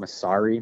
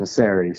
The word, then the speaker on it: Massare: A lot of people give me a hard eye on my last name and call it Massare, but I'm not Polish. Yeah, I'm 100% Italian. So, it's pronounced Massare.